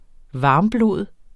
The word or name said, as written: varmblod